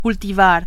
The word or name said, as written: cultivar